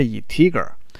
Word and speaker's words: integer: 然而整形包装类呢是 integer 啊。啊，这个其实我一直发音不太对啊，这应该是 integer 啊。